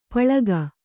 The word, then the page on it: ಪಳಿಗೆ: Kannada